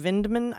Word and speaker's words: Vindman: I'm just saying Vindman. I'm just pronouncing the D, the hard D.